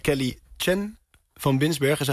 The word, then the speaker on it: Kelly Chen van Binsbergen: Een programma van Kelly Chen van Binsbergen, zeg ik het goed? – Ja.